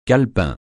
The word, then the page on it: calepin: francais